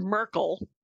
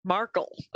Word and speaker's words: Markle: Not Merkel. Markle. Okay. – No, it's not Merkel.